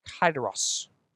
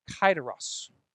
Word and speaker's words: kairos: There's several ways to pronounce it, but I'm going to give you the easiest one I can, okay? Kairos. Kairos. And it means this, an appointed time, an emphasis of a special time given to you by the master.